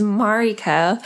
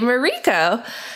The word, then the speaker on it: Mariko: But Eastern names, in Japan, tend to have an emphasis on the first syllable. So Mariko instead of Mariko.